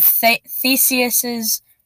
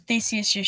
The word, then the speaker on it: Theseus: So, Theseus does make sense, but much like Dr. Seuss is spelled, when I was a kid, I thought you said it Theseus.